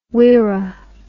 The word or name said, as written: wirrah